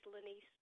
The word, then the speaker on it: Lenise: No, actually, it's Lenaise. Just like Denise with an L.